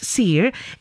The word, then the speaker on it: Siir: Well, it is spelled S-I-I-R and pronounced Seer.